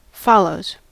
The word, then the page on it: follows: American English